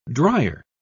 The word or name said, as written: drier